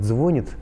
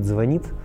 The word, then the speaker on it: звонить: Вот, если человек понимает, что неправильно говорит «звонит», а правильно говорит «звонит», значит, у него в голове есть представление о норме.